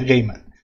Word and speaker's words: Gaiman: Entonces, ya digo, no respondo por la calidad de la obra, porque si Neil Gaiman, que me he enterado recientemente, se pronuncia Gaiman.